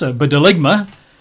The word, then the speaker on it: bdelygma: And in terms even of the language, the word abomination in Greek is this rather horrible word to pronounce, bedeligma, B-D-E-L-Y-G-M-A. The word desolation in the Greek is eremosis, E-R-E-M-O-S-I-S.